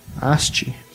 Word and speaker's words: Asti: é a Adriana Asti, Asti, seria a pronúncia do, do italiano, mas ela é uma atriz que já trabalhou com, trabalhou muito com Pasolini.